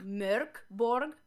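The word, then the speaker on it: Mörk Borg: It's Swedish, so it's Merk. – Merk. – Merk Borg. – I can't roll my tongue. – I don't pronounce it as a Swede, so. – Oh, okay. So Mork Borg is fine then. – Reading the Swedish.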